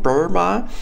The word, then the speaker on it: Burma: Oder Burma, also im Englischen wird es ja gleich ausgesprochen, Burma.